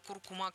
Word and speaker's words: куркума: Ну и последнее ударение в слове «куркума» изменилось. Большой словарь ударений русского языка закрепил вариант «куркума» как основной, сообщил научный сотрудник Института русского языка имени Виноградова РАН, председатель филологического совета тотального диктанта Владимир Пахомов.